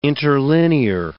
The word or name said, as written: interlinear